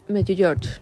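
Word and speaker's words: Međugorje: Medjugorje.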